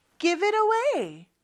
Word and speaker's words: give it away: 근데 T 발음이 안 들려요. 그쵸? 자, 이것도 연음이 돼서 그래요. 일단 앞부분 연음되는 건 어렵지 않아요. give it. give it. 그런데 T가 모음 소리 사이에 껴있거든요. 그럴 때는 real처럼 발음을 해줘서 give it away 이렇게 발음이 되는 거예요.